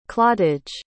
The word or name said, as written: clottage